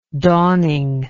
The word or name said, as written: dawning